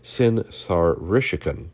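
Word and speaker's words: Sin-shar-ishkun: The Assyrian king Sin-Sharkuskum, let me make sure I pronounce that correctly, Sin-Sharishkin,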